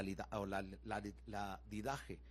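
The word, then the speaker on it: Didaché: La Didaj o la, la, Didaje, porque está eh, pero puede ser, se pronuncia ladidaje.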